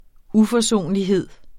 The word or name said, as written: UFORSONLIGHED